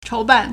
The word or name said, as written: chou2ban4